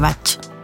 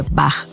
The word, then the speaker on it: Bach: Lo común que oímos siempre decir, ¿cierto?, es flores de Bach. Bueno, te voy a decir que la correcta forma de pronunciarlas es flores de Bach con CH como de chocolate. Bach es la forma de pronunciar este apellido en Alemania, como por ejemplo el músico Juan Sebastián Bach.